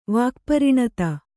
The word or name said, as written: ವಾಕ್ಪರಿಣತ